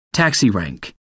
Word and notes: taxi rank: American English

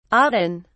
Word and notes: auden: British English

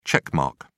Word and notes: check mark: British English

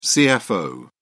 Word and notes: cfo: British English

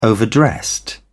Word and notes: overdressed: British English

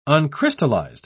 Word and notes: uncrystallized: British English

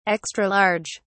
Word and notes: extra large: British English

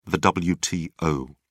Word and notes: wto: British English

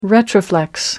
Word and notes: retroflex: American English

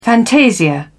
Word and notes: fantasia: British English

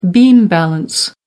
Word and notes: beam balance: American English

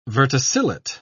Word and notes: verticillate: British English

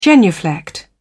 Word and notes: genuflect: British English

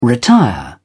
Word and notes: retire: British English

